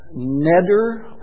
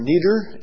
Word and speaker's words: neder: And that's a neder, or neder, N-E-D-E-R is how I'm going to spell that.